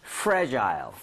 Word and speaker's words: fragile: Fragile. – Fragile? – 뭐, fragile이라고 발음하는 사람도 있고 fragile이라고 하는 사람도 있는데요.